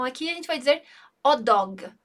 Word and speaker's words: hot dog: A gente não vai pronunciar esse som aspirado do R ali, o H não vai ter esse som. Então a gente também emenda o un aqui junto com o dog.